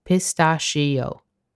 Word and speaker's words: pistachio: In both America and the UK, pistachio is pronounced as four separate syllables, pistachio. In Italian, it's just three syllables.